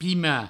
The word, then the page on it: sepimar: sepima